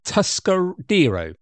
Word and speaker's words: Tuscadero: She was Lever Tuscarido, or Tuscadero. There we go. Get it pronounced correctly.